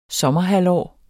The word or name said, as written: sommerhalvår